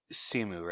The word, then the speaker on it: Simu: Simu, right? That's how you say his name? – Yeah.